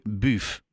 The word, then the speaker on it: BUUV: Linda Wegman, directeur van VWC BUF. Nu zeg ik het goed, hè? – Je zegt het zeker goed. Eh,